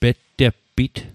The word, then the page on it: petteppit: Mi'gmaq